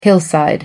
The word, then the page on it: hillside: American English